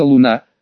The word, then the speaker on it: Луна: а также «Принцесса Луна», с ударением на «У», за все ее возгласы,